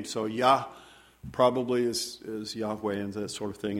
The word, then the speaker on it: Yahweh: And the word in Hebrew is Yahweh. Sometimes it's pronounced that way. But in the original Hebrew, there's no vowels. So no one is exactly sure what it sounds like.